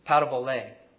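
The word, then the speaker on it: Parabole (παραβολή): This Greek word here for example or illustration, this is how you would pronounce the word. And you tell me, just think about what this word reminds you of. Parabole. This example, this illustration, the Greek word is parabole.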